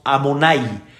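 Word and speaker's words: Amonai (אדני): Ustedes conocen muy bien, cada vez que vemos este nombre sagrado, yud Vavke, vav en vez de decir el nombre como está escrito, nosotros decimos Amonai. Amonai significa patrón, patrón.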